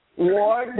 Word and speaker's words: Ward: Ward, W-A-R-D.